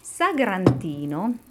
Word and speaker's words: Sagrantino: Sagrantino is the name of a grape and the red wine made from it, primarily around the town of Montefalco.